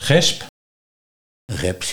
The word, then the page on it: Geps: Nieuw lekkerlands